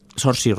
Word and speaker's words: Saoirse: ¿Sorsi? – Saoirse Ronan, sí, sería más...